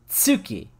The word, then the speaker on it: tsuki: And the word for moon in Japanese is tsuki. Like, you pronounce it with a T at the beginning.